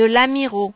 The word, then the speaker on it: Lamirault: 2 rue de l'Amiraud.